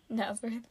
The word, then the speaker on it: Nazareth: Nazareth.